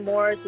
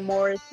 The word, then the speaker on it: Morris: My YouTube name is Amaya Morris. It's spelled A-M-A-Y-A, last name Morris, M-O-R-R-I-S, a.k.a.